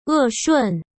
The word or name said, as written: E Shun